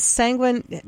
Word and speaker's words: Sanguine: And what about Sanguine? Am I pronouncing that right? Sanguine Sally? – Yes.